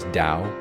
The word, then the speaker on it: Tao: It's called The Tao of Healing, because my first name is Tao, D-O-W, you see. And the Tao in Taoism, which is spelled T-A-O, actually, the Tao in Taoism means the way.